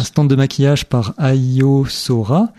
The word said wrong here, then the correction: Aoi Sora: Non, Aoi Sora. – Pardon, j'ai mal prononcé.